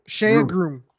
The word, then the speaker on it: Shea Groom: Shea Groom.